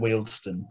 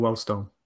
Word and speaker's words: Wealdstone: At Wealdstone, that's how you pronounce it. – I'm going Wellstone.